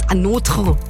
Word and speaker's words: ANOTR: Mäuse, bei denen du dich erstmal durch Interviews klicken musst, in denen ihr Name gesagt wird, weil wenn ihr nur lest, Anotre, so schreibt man die nämlich, A-N-O-T-R, so, hä? Wie spricht man das? Anotre. Hat ja nicht mal eine richtige Logik auch, ne?